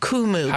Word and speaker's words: Kumu: You said the app is called Kumu? – Kumu. – How do you spell that? – K-U-M-U.